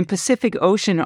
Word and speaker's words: Pacific Ocean: It's interesting that all the C's in Pacific Ocean are pronounced differently.